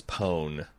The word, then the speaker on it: pwn: And some people pronounce that as pwn. I think it's because O and P are right, so it's kind of like it's a fat finger typo thing, yeah. – Yeah, yeah, yeah. But I didn't think seriously you would pronounce it as pwn.